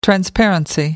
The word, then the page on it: transparency: American English